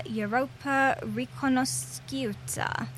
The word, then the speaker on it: Europa Riconosciuta: Opened in Milan with a performance of Salieri's opera Europa Riconosciuta.